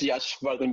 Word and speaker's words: Yashvardhan: Yeah, it's Yashvardhan, but that's fine, completely fine, no issues.